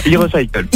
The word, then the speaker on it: e-Recycle: Hein et euh, vous faites partie euh, de e-recycle, j'espère que je bien prononcé. – C'est ça, e-recycle.